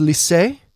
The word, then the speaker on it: Liss: And I wanted to point people, if you haven't seen it, someone named Casey, I don't know how to pronounce his last name, L-I-S-S. Casey, I think it's French. It's Lisse.